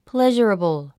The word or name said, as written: pleasurable